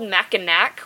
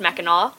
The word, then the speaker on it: Mackinac: It's spelled Mackinac with a C, but it's pronounced Mackinac.